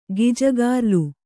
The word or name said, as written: ಗಿಜಗಾರ್ಲು